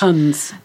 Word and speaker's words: Hons: I don't really know how to pronounce that because in Jessica Mitford's book, she says it's more to do with hens. So you pronounce the H. – Hons.